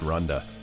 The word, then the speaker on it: Randa: It's spelled Randa, R-A-N-D-A, but it's pronounced Rhonda.